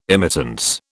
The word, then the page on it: immittance: British English